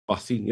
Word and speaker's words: Racine: I grew up saying it Racine, but half the people that I knew said Racine. And I think that's still the case. Like half that area pronounces it one way and half of it pronounces the other.